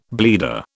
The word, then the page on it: bleeder: British English